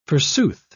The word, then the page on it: forsooth: British English